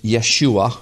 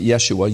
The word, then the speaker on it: Yeshua: It spells Yehoshua, also known in Hebrew as Yeshua, or some people pronounce it Yeshua, Yeshua, Yeshua, Yehoshua, all the same thing, translated to English as Jesus.